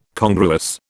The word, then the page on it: congruous: British English